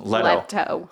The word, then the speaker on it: Leto: Jared Leto? Leto? What have we decided is the proper pronunciation? – Leto.